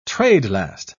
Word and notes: trade-last: British English

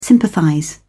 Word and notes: sympathize: British English

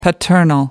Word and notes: paternal: American English

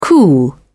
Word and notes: cool: British English

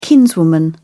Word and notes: kinswoman: British English

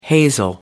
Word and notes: hazel: American English

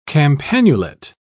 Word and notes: campanulate: British English

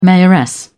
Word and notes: mayoress: British English

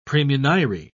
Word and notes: praemunire: British English

